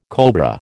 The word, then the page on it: cobra: American English